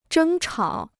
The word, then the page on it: 争吵: zhēng chǎo